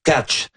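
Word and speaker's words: Tkáč: Et puis là, ben, il faut pas le prononcer Anton Tack. On avait tout fond en 76. – C'est ça. Selon ce que Juraj Benko, là, un auditeur d'origine slovaque, m'a dit, c'est Anton Catch.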